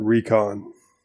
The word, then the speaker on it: Raikon: I know that it's spelled Raikon, but he actually says it Recon.